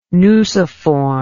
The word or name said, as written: nuciform